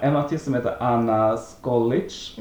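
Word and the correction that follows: Soklič: Mm. – Soklic menar jag. Soklic. Vill jag uttala det som, men ja, Anna.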